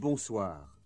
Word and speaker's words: bonsoir: In this section, pay particular attention to the R sound in au revoir and at the end of bonjour and bonsoir.